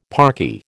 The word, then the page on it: parky: American English